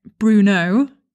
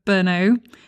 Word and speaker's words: Brno: And in fact, the whole time when I was visiting Brno, I kept pronouncing it Bruno instead of Brno.